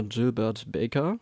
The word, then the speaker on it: Gilbert Baker: Die wurde nämlich 1978 von dem Künstler und Designer Gilbert Baker, habe ich richtig ausgesprochen. Danke.